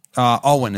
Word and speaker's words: Eoghan: Ian Egwan? – I don't know. – E-O-G-H-A-N. – Owen is how that's pronounced.